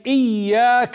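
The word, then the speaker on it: إياك: So when you say إِيَّاكَ نَعْبُدْ, إِيَّاكَ نَعْبُدْ, see how I'm stressing on the ya. إِيَّاكَ نَعْبُدْ, meaning I worship you, O Allah. إِيَّاكَ. If you say إِيَّاكَ, إِيَّاكَ, without the stress on the ya, without pronouncing the ya as doubled, you say إِيَّاكَ.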